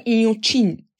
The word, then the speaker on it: Inhotim: Kendi telaffuzlarını e, denemeye çalışırsan Inhotim diye telaffuz ediliyor Portekizce'de ya da Brezilya Portekizcesinde.